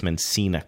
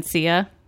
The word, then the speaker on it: Mencia: Can we get through one episode without you playing a Carlos Mencia commercial? – Do you mean Mencia? – That would have been funnier if I pronounced his name right.